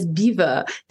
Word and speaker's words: Belvoir: But I'm sure you say it is pronounced, the name Belvoir, meaning beautiful view in French, and pronounced today as beaver, dates back to Norman times.